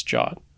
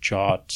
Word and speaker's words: JWT: I think it's jot. – Really? – Jwt. – I've literally only ever said JWT before. – I think I read on a blog somewhere that it's pronounced jot, so I took that as authoritative because if you read it on the internet, it's got to be true.